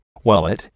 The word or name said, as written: wallet